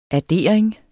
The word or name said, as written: addering